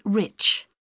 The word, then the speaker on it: Rich: It's Mrs. Rich. – How do you spell that? – R-I-C-H.